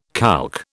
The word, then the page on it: calque: British English